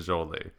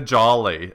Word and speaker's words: Jolie: So you can't be the one to be like fighting with us about the pronunciation of Angelina Jolie. You call her Angelina Jolie as if she's bouncing around happily. – Angelina Jolie. – There you go. That's right. You got it right now, but that's literally the first time in 100 episodes.